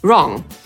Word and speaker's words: wrong: To zależy, jak na to spojrzeć, bo jeżeli słowo zaczyna się w pisowni na WR, no to wtedy W nie wymawiamy. Tak jest na przykład ze słowem pisać, czyli write, albo niewłaściwy, czyli wrong. Tak samo, jeżeli słowo zaczyna się na zestawienie WH, to wtedy też W nie wymawiamy.